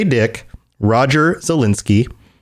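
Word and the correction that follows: Zelazny: Roger Zelezny, right? That's how you pronounce it. – I think it's Zelezny. I've always said Zelezny. Zelezny. I who knows? – Yeah, Zelezny. John knows.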